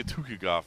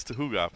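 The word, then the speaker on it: Tukhugov: I always say Tukigov. It's Tuhugov. But Hakeem Duwadu.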